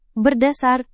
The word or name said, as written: berdasar